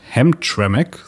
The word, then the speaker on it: Hamtramck: Im US-Bundesstaat Michigan, genauer gesagt in der Stadt Hamtramck, so wird die meines Wissens ausgesprochen, spielt sich Spannendes ab.